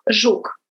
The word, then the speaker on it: Zhuk: It's Alena Zhuk.